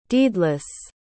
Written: deedless

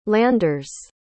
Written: landers

